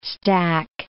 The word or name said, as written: stack